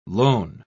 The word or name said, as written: loan